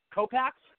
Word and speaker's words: Kopacz: Dave Kopax. How are you, sir? Welcome to the show. I hope I pronounced your last name right. Kopax? – Yep, Kopax.